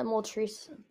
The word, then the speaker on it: Moltres: And this one might surprise you, but Moltres, and I call it Moltres, but like, yeah. Apparently the Pokemon Company says it's Moltres? Bro, it's spelled Moltres.